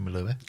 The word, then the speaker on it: Melua: Is Katie Melua or Katie Melua? – Melua.